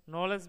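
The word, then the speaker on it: knowledge: जैसे नाइफ में के साइलेंट है, साइकोलॉजी में पी साइलेंट है, नॉलेज में के डब्ल्यू और डी तीन ध्वनियाँ साइलेंट है।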